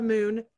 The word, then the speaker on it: Moon: That I often say, hi, I'm Laura Moon, like in the sky.